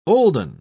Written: olden

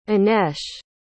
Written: inesh

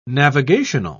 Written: navigational

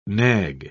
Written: nag

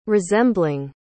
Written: resembling

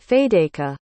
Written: Fadeyka

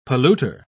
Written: polluter

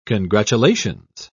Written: congratulations

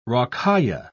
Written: rocaille

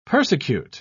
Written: persecute

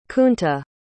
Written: Kunta

